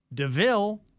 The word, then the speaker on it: Devil: Another named Devil, D-E-V-I-L, pronounced Deville.